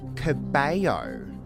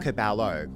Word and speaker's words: Cabello: The correct pronunciation is Camilla Cabello. – Oh. – It's not Cabello. Yeah. There's no L. You don't pronounce the LL.